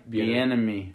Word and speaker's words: Bieniemy: Bieniemy.